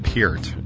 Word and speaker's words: Peart: The drummer, drummer Neil Peart, as I would come to find out it's pronounced later on, um, arguably one of the greatest rock and roll drummers of all time.